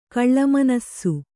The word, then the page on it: ಕಾಳ್ಮಾನಿಸ: kāḷmānisa